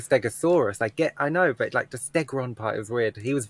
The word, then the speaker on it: Stegron: Like, Stegron, correct, yeah.